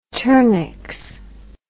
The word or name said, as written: turnix